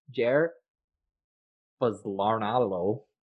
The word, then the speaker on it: Jair Bolsonaro: Last year, Brazil's anti-gay president, Jair Bolsonaro, I'm sure I pronounced that incorrectly, but he's anti-gay. – You nailed it.